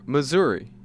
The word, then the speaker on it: Missouri: M-I-S-S-O-U-R-I. – Missouri. – Say it again. – Missouri. – Missouri. Okay. There are people who say Missouri.